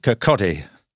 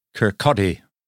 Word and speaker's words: Kirkcaldy: So you have to pronounce it Kirkcaldy. Kirkcaldy.